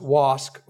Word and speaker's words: wasf: And wasq, W-A-S-F is spelled, but it's pronounced wasq with a hard K sound on the end.